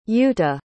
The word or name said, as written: yuuta